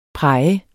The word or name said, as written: praj